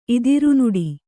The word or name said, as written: ಇದಿರ್ನೋಡು